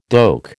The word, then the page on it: doek: American English